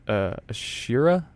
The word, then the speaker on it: Ashura: The album cover, it features an Islamic festival called Ashura, I believe it's pronounced.